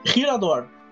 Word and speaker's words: Hiradhor: Minhas redes sociais são Nigel Cap no Instagram, Nigel Capelari, pode me encontrar pelo Facebook, e Rirador, H-I-R-A-D-H-O-R.